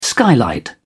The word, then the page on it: skylight: British English